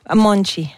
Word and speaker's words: Monte: D'accord, et ce matin, c'est Marisa Monte. Ok, m'importa. – Oui, Marisa Monte avec son Ok, m'importa. On dit Monte? – Monchi. – Monchi, ça s'écrit M-O-N-T-E pour les intéressés.